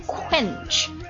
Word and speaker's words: quench: 发音的时候，你的嘴角肯定是向两边扯的 quench。